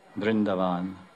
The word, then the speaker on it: Vrindavan: Vrindavan, to be exact. The pronunciation has changed with the influx of non-Hindus. Although it was originally Vrindavan, even Indians now say, I'm going to Vrindavan.